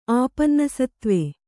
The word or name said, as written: ಆಪನ್ನಸತ್ತ್ವೆ